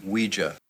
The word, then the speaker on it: Ouija: Because he brought a Ouija board to somebody else's party because he's that desperate for attention. – It's pronounced Ouija.